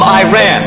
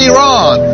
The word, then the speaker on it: Iran: She is from Iran. – Excuse me, pastor. It's actually pronounced Iran.